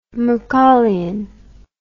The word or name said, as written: macaulayan